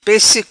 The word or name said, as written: Pesse